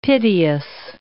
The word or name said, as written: piteous